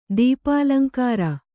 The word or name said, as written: ದೀಪಾಲಂಕಾರ